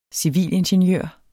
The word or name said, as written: civilingeniør